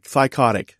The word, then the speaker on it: Thycotic: I think you were maybe a year or two, maybe a few years into your career with Thycotic. Did I say that right? Thycotic.